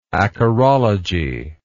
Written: acarologist

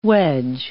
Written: wedge

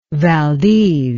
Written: Valdez

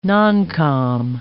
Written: noncom

